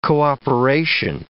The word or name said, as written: cooperation